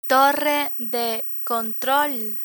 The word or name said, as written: torre de control